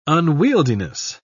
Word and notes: unwieldiness: British English

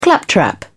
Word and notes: claptrap: British English

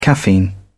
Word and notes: caffeine: British English

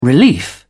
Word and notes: relief: British English

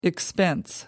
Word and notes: expense: American English

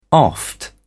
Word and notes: oft: British English

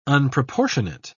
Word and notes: unproportionate: British English

